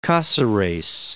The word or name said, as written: Caceres